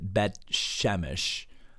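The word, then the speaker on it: Beth Shemesh: The Bible says in verse number 13, and they of Bet Shemesh, some people pronounce it Bet Shemesh, were reaping their wheat harvest in the valley.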